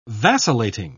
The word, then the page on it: vacillating: British English